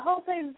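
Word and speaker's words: José: And I think it's Jose, because it's spelled J-O-S, and it has E, and it has a hyphen above the E. That's Jose, right? – Yeah, Jose Silva.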